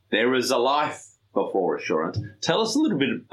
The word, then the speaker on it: Assurant: Have I got the pronunciation right? Assurant? – Yes, you do. That's correct.